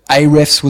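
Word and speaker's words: Ahrefs: So AREFS was a turning point for us, which is spelled A-H space R-E-F-S, where we could look at, you know, we had to specialize in a sector.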